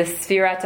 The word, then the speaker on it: Sfira: So it's the Sfira to Omer, the counting of the Omer. Okay. That's how you say it in Hebrew.